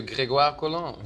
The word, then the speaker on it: Grégoire Colin: And we have an actor coming along as well, Grégoire Colin. Is that how you pronounce his name? – Monsieur Grégoire Colin.